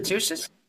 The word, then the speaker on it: Xerces: It's from Gaina, and it says, as the Xerces Society, X-E-R-C-E-S. How does it pronounce that? Xerces? – Xerces is the way I say it. X-E-R-C.